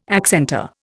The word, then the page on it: accentor: Indian